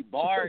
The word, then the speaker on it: Bar: They be like Bear 9 or maybe like this like Bar with one R. But really, Bar is my last name.